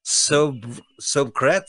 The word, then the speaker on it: sobriquet: Sobriquet. – It's S O B R I Q U E T. It's like a soliloquy, like a sob.